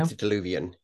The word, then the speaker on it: antediluvian: the antediluvian. Did I say that right? – Antediluvian, yeah.